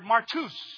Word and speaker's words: martus: Everyone write down the word, in your Bible if you want, transliteration of the Greek word witness, just write down the word martus. M-A-R-T-O-O-S is how you pronunciate it. M-A-R-T-U-S is how it's transliterated. But martus. M-A-R-T-O-O-S. Martus.